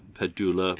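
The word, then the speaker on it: Pedulla: pedula bass. Is that the right way to say it? – Yeah. – Or pedula. Pedula. – It's a pedula.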